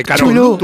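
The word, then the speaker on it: Cthulhu: ¿Cómo se dice? – Chulú. Chulú. – A son dos sílabas. Son dos sílabas. – Chulú, yo creo. Yo siempre he dicho Chulú, los mitos de Chulú.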